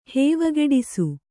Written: ಹೇವಗೆಡಿಸು

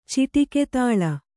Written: ಚಿಟಿಕೆತಾಳ

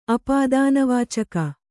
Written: ಅಪಾದಾನವಾಚಕ

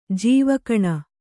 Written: ಜೀವಗುಣ

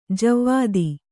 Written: ಜೀವಿತ

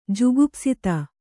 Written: ಜುಗುಪ್ಸಿತ

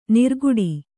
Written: ನೀರ್ಕುಡಿ

